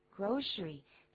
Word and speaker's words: grocery: 嗯哼，不过在字典里头你可能会发现它的这个注音念成 grocery，但实际上我们可以听到 刚才的念法，也就是很多美国人的念法，他是这么念的 grocery grocery 这个字还蛮好用，把它变复数，就是 y 去掉加 ies groceries，那就是菜的意思，去买菜。